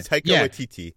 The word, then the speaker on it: Taika Waititi: And I don't know if he'd reported it or not, but Takika. How do you say that guy's name? – Taika Waititi.